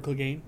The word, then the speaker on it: Clegane: Clegane? – Gregor Clegane.